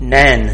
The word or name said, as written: नैन